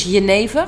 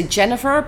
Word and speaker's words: genever: In, in Dutch is Geneva. – Geneva. Okay. – So Geneva sounds the best way. Some people say Jennifer, but no.